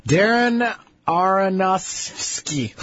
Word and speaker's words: Aronofsky: Darren Aronofsky. – That's right. That's how you say it. – Okay. Aronofsky.